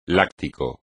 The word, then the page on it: láctico: Spanish